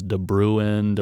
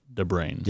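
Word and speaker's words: De Bruyn: And I thought it was DeBruin. It's D-E and then B-R-U-Y-N. – Right. – Pronounced DeBrain.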